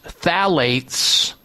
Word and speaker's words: phthalates: If you see something that looks strange, that you have a hard time pronouncing or understanding what it is, phthalates, spelled P-H-T-H-A-L-A-T-E-S, phthalates, don't use it because it jacks up the risk of diabetes in women by a whopping 60%.